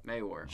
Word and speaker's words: Mawhorr: Mawhorr.